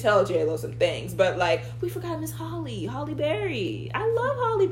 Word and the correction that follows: Halle: It's not the same thing. Halle? You have to pronounce her name right. – Halle? – Mm-hmm.